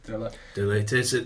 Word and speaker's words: De Ligt: De Ligt.